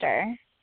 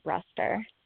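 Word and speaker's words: Bruster: Brewster. – Brewster. Okay. – We're the different side of the Arm Brewster family. We say Brewster.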